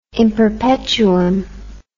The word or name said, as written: in perpetuum